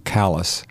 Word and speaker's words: Calais: Unfortunately, it's spelled like Calais, C-A-L-A-I-S, but it's pronounced locally Callis, which is a very...